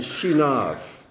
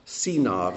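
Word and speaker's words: Sinav (שנאב): And Shinav. Sinav would be better pronunciation.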